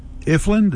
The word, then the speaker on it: Ifland: And Mark and Carrie Ifland, did I say that right? – Yeah, that's it's spelled that way.